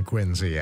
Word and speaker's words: Quincy: Historian Harlow Giles Unger wrote a biography of John Quincy Adams. Quincy, incidentally, is how the family pronounced it.